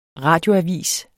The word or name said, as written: RADIOAVIS